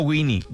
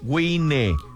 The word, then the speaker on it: Wine: Visite en Facebook Baja Wine, se escribe Baja Wine, no Winnie, Wine, Baja Wine, Baja Wine.